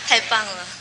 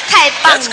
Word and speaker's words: 太棒了: Wait, how do you say that? – 太棒了。 – 太胖了。 – Right? You sound like too fat. – Too fat. I am too fat. Okay. – No. 太棒了。